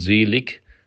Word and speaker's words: selig: It's written selig, but if you sing it in German, you pronounce selig sind die da Leid tragen, denn sie sollen getröstet werden.